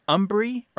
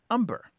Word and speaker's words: hombre: And this is that there has been a long history in the United States of a very anglicized pronunciation of the Spanish word hombre, meaning man. And those two pronunciations are umbri or umber.